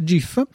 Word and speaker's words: GIF: G con la G dolce perché io sono un sostenitore della, della pronuncia GIF che farà arrabbiare qualcuno e qualcun altro no.